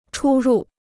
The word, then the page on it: 出入: chū rù